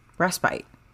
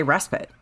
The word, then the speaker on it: respite: It's the British pronunciation is respite. And in America, we say respite.